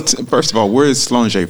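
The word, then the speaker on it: Sláinte: Well, but what I should be saying, at least approaching a more correct pronunciation is Slonjé. – Slonjé.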